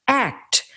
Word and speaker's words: ACT: It's known as ACT, which he recommends pronouncing as the word ACT.